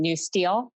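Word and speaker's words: NUSTL: It's pronounced by its acronym, NewSTEL.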